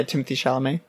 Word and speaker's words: Timothée Chalamet: That's how you say his name, right? – Yeah, Timothée Chalamet.